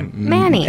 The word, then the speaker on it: Many: It's spelled... Not a clue. – M-A-N-Y. – Oh, okay. – Manny. – And it's Manny.